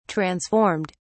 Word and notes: transformed: British English

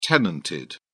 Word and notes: tenanted: British English

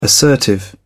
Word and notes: assertive: British English

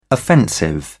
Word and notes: offensive: British English

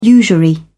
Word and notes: usury: British English

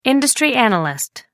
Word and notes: industry analyst: American English

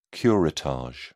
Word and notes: curettage: British English

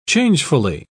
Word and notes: changefully: British English

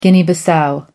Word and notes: Guinea-Bissau: American English

